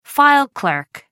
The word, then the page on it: file clerk: American English